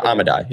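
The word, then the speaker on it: Amodei: Amodai, yeah.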